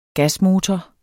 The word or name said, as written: gasmotor